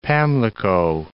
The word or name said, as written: Pamlico